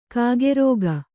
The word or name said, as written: ಕಕ್ಕರಿಕೆ